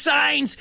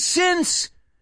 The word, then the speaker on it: since: No es since, es since. ¿Cómo se dice pecado? Sin. Pues ponle un s al final. Since. Since.